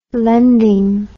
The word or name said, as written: lending